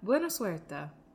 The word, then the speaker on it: Buena suerte: Buena suerte. Is that how you say it? – Yeah, you got it.